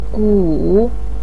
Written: gu3

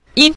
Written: yin5

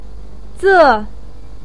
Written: ze4

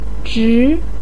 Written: zhi2

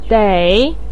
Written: dei3

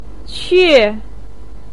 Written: que4

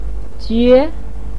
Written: jue2

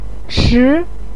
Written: chi2

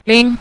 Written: ling5

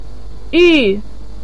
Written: yu4

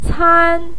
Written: can1

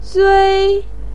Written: zui1

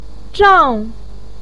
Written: zhang4

